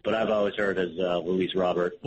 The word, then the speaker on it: Luis Robert: I've always heard it pronounced as Luis Robert.